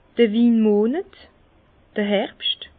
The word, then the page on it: d'r Winmonet, Herbscht: Bas Rhin; Herrlisheim